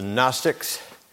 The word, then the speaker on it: Gnostics: The Gnostics. Don't ask me why G is silent.